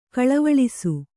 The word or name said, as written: ಕಳವಳಿಸು